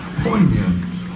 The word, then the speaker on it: poignant: And it's got a silent G in there for those of you that are smart. Poignant. With a good in there for no reason.